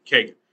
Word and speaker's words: Kagan: Is that how you say your name? I'm trying to figure out how you say your name. I keep forgetting how you say. Justice Roberts, Kagan. That's how you say your name. Kagan.